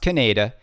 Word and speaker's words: Kaneda: Kaneda Pinball Podcast is telling everyone that Keith Elwin is working on Godzilla, and it's pronounced Kaneda.